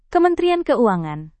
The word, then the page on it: kementerian keuangan: indonesian